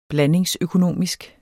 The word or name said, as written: blandingsøkonomisk